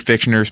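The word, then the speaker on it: Fichtner: Am I saying that wrong? – Fictioner. – Okay, good. – Do you hear the difference? – Yes. Do it again. – Fictioner. – Fictioner. Thank you.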